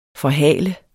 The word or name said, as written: forhal